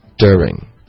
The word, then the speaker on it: during: 所以这个是它的英式的发音是一个的 ya 的音，对吧？During 它的标准的发音，官方的发音是 during，所以我们可以用一个这个变音 during 可是更常见的方法呢，就是发生什么呢？就是 during，就是这个 dur 的这个发音就直接浊化这个音节，整个音节的发音变成 d，对，这是一个 d d，这是一个比较美式的发音，可是确实英国人也会这么发，就是 during，然后这个时候就不用想成一个变音了，因为它就是发 d 而不是 dear 的这个 y 的音了，对吧？ – 所以这个 DJ 的话，理论上说有三个音可以发。